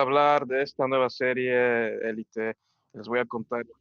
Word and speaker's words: Elite: Um, een daarvan is de Elite, dat is in het Engels uitgesproken natuurlijk, maar in het Spaans is Elite, met accentje op de E.